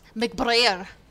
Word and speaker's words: McBrayer: My last name has been and always will be pronounced McBrayer. – How do you pronounce it? – McBrayer. – Okay, that's what I've been saying. So good. – Yeah, you're right. Like prayer, but with a B.